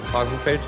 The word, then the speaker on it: Frankenfeld: Also weil wir gerade von Namen sprechen, mein Name ist Frankenfeld. Also es wird immer falsch ausgesprochen. Wie Krankengeld, nur nicht so dick.